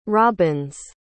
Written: robbins

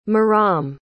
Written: maram

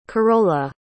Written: carola

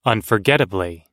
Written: unforgettably

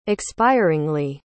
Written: expiringly

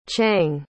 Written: chiang

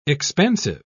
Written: expansive

